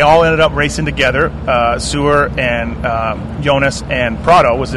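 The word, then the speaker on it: Seewer: People get on me for saying, that's how you say it, though. – Yeah. – Sewer. – Yeah. Yeah, okay. – Swiss kid, Swiss name.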